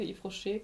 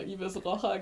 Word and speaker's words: Yves Rocher: Ähm, und da haben wir zum Nikolaus, ich weiß nicht, ob du die Marke Yves Rocher kennst. Ja, die kenne ich. Früher habe ich immer Ibis Rocher gesagt, weil ich es nicht aussprechen konnte.